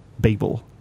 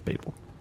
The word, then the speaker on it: Babel: As it is pronounced in the lead single. – It's not how that movie title is pronounced. – Babel. – Yes. – I would have gone with Babel, but then when I listened to it again this morning, they'd clearly say Babel, so I'm going with Babel.